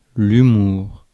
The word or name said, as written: humour